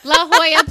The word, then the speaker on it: La Jolla: La Jolla. – La Jolla Playhouse.